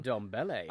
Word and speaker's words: Ndombele: For Liam Ndombele. I'm enjoying the way you pronounce that, very much. It's correct.